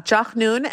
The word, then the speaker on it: Jachnun: And there's this other bread named, pardon me if I'm pronouncing this incorrectly, but Jachnun? – Yeah, Jachnun.